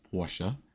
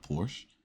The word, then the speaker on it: Porsche: It's Porsche, not Porsche, you know, and it's Audi, not Audi.